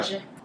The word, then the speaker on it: Oger: Is it O-G-E-R? How do you say it? Am I speak French? – Wait, O-G. – Auxerre. O-G-E-R? Auxerre? – Mm-hmm. – Yeah. Auxerre. So Les Mesnil, sur Auxerre.